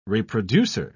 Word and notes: reproducer: British English

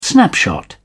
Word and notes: snapshot: British English

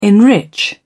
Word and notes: enrich: British English

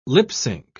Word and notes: lip-sync: British English

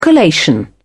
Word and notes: collation: British English

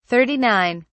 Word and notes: 39: British English

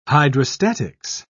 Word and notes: hydrostatics: British English

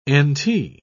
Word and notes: nt: British English

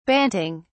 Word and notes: banting: British English

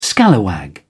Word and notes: scalawag: British English

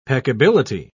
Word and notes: peccability: British English

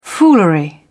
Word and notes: foolery: British English

